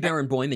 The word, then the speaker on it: Barenboim: Well, Daniel Barenboim, and yes, you did say his name correctly,